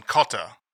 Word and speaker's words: Cottar: He also responded to the Calvin Carter episode. – Oh, Calvin Cotter. Oh, family, yeah. The Cotters. – Spell that, because your accent. – Cottar, Cotter. – Oh, I've been saying it wrong.